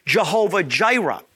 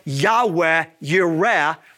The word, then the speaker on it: Yahweh Yireh: They mispronounce it and call Him Jehovah Jireh. When the church calls God Jehovah Jireh, that is the mispronunciation of Yahweh Yireh from Genesis 22:14.